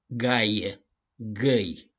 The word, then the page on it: GAIE: GĂI